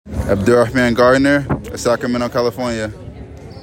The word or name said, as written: Abdurrahman "Manman" Garner